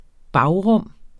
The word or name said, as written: bagrum